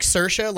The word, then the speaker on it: Saoirse: So she's like, I just tell people it's like Saoirse, like inertia.